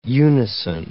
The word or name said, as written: unison